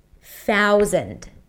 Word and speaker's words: thousand: Theory, thousand, thousand. Now that might be confusing with the Z sound in the middle. And yes, it's a Z sound, even though it's spelled with an S. Thousand.